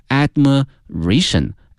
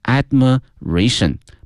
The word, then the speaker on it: admiration: 那么看看这个词动词呢，羡慕 admire， admire， A-D-M-I-R-E admire，但是呢变成了名词之后同学们一定要小心了，他的这个发音发生了变化，叫 admiration， admiration 表示对一个人特别的羡慕哈，特别的喜欢 admiration。